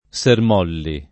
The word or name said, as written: Sermolli